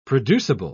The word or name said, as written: producible